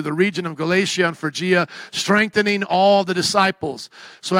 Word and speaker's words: Phrygia: Now, I would normally say Pergia. But it is not Pergia, and it is silent. It's like an F here. Phrygia. Something like that. What are you going to say? Phrygia. It's an F sound, like phone there.